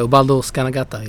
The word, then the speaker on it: Ubaldo Scanagatta: Ubaldo Scanagatta, is that the right pronunciation? – Ubaldo Scanagatta is the right pronunciation.